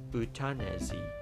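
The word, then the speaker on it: euthanasie: ازش سخن به میون میاد میگن اوتانازی در فرانسه اگر من درست تلفظ بکنم میگن اوتانازی که همون ما فارسیش کردیم اوتانازی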